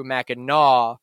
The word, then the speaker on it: Mackinac: But the way it's spelled is Mackinac. Uh-huh. And there, I don't believe there's any language where the NAC makes the gnaw noise.